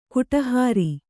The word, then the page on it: ಕಡೆಹರಿ: Kannada